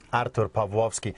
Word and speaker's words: Artur Pawlowski: And he is now visiting us from Calgary, Alberta, Canada, where he pastors a church, Pastor Artur Pawlowski. Am I saying your name correctly? Give us the traditional Polish pronunciation. – Artur Pawlowski.